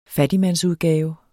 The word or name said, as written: fattigmandsudgave